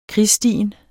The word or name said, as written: krigsstien